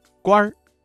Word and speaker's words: 官儿: 这句朝廷上当官的认为谢安能够治国平天下，这句话当中啊，当官一词的官字加了一个卷舌音日，发生了语流音变，形成了儿化音，读作官儿。